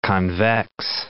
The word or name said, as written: convex